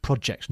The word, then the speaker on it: project: Now, you have to say project correctly, and it's pronounced project, not project.